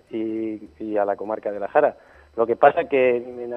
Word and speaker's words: La Jara: La Jara, que pertenecería a su vez a la comarca de Talavera.